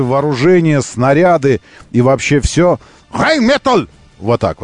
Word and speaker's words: Rheinmetall: Ее надо так произносить из горла, как бы. Rheinmetall! Rheinmetall! Вот так она называется.